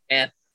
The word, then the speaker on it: Aniyay: First off, you want to say you said it wrong. You said Aniyay twice. It's Aniyay, Aniyay, man.